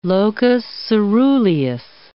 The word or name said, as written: locus ceruleus